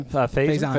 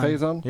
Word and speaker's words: Faison: Donald, how do you say his last name? – Faison. – Faison? – Yeah.